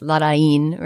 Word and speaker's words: Larraín: And if you did a little bit of research into what the accent means, you could figure out that it's Larraín, right, with that accent on the end.